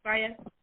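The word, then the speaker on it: Faiyaz: Ooh, do you pronounce it Fias? – Yeah. – Fias.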